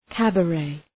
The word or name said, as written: cabaret